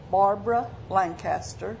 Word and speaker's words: Barbara Lancaster: Pat Cobb, P-A-T-C-O-B-B. – Barbara Lancaster, B-A-R-B-A-R-A, L-A-N-C-A-S-T-E-R.